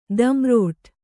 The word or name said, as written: ದಂರೋಟು